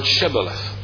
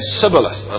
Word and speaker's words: Shibboleth: And the reason that they are singled out is their inability to properly pronounce the word Shibboleth. They did not properly pronounce the first syllable. They said Sibboleth, and I wonder how many times I'm going to get it mixed up tonight in preaching it.